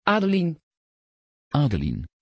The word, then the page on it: adelyn: Dutch